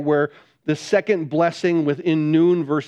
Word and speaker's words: nun: And so I know yours, you know, it's N-U-N. Some people say it's none. It's not. It's a capital U. It's a long U. It's a hard U. So it's noon is how the word is it.